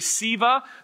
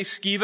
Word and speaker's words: Sceva: Seven sons of the Jewish high priest named Sceva, or you can say Siva. That some pronounce it that way. I say Sceva because it reminds me of scabies, which is a word that makes you feel uncomfortable.